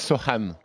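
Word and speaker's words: Sochan: On prononce Sochane.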